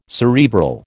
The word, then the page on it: cerebral: American English